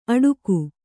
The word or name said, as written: ಅಟೂಕು